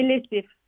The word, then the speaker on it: Lézif: Est-ce que je prononce votre nom comme il faut d'ailleurs? Je voulais juste vous le demander. – Oui, bah, c'est Lézif. – Lézif, d'accord.